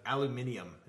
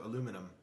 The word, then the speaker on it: aluminium: I'd like aluminium is what they say in England, in the UK. They say aluminium instead of aluminum, which is how it's spelled.